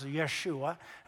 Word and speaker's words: Yeshua: The name Jesus, the name Joshua, which, by the way, the Jews pronounce Yeshua today, means God saves.